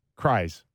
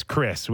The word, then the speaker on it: Krys: It's spelled K-R-Y-S. – Kris won. – Which looks like cries. – Yeah, it might be cries. – That's a bad name. That's a bad pronunciation for a hockey player. – Two first names. – Yeah, you can't. – This guy cries about it. – It's Kris. We know it's Kris because, you know, Tom Hanks said there's no cries in baseball and there's no cries in the NHL.